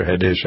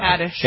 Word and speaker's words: Haddish: Is it Haddish or Haddish? I never pronounce it. – Haddish.